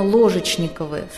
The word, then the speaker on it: Ложечниковы: Изначально фамилия этого рода была именно Ложечниковы, с ударением на первом слоге с буквы «О».